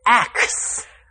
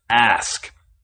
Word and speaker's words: ask: Too often I hear people say axe. – Now, we know it takes extra effort with the tongue to generate that K sound right after the S. – We also know there are more and more people who are defending the axe pronunciation, saying it's acceptable. – But as English teachers, we must insist on its standard pronunciation, ask.